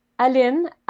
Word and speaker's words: Aline: No, Aline. I like Ooh. Allison, and then Lynn.